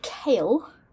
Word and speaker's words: Chaol: It's written Cole. They say Kale, and I don't know why. – Kale is in the food? – Kale is in the food. But it's spelled C-H-A-O-L, Cole.